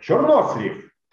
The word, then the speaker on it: чернослив: И говоришь «чернослив» или «чернослив». Нет, «чернослив». Ёлки-палки.